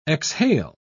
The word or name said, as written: exhale